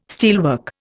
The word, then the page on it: steelwork: Indian